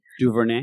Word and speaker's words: DuVernay: Ava DuVernay. DuVernay. – DuVernay.